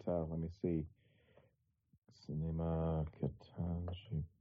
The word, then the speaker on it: Ketanji: I guess we'll flip it around to D.C., where we had more hearings for Ketanji Brown Jackson. Ketanji? Am I pronouncing that right? – I think it's Ketanji Brown Jackson, yeah.